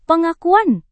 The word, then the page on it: pengakuan: Indonesian